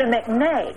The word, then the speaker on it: McNay: I visited the McKay. Is it the McKay? – The McNay. – McNay Art Gallery. Pardon my mispronunciation.